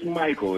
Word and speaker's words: Mychael: First of all, Michael is spelled M-Y-C-H-A-E-L. Is that Michael or is that Michael or? – So it's just Michael.